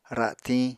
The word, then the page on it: ra7tiñ: Apatani